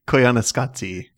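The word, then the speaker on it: Koyaanisqatsi: Koaniskatsi? – Koyaniskatsi.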